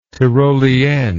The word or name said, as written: tyrolienne